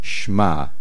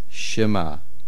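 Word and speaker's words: Shema (שמע): The word is not Shma, it is Shema. It is one syllable.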